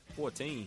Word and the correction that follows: Evan Fournier: Evan Fournier, brother.